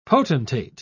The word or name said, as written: potentate